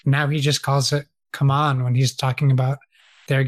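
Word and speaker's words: CMON: Now I've been pronouncing it wrong forever. They issued a statement saying it's called Come On, not Simon.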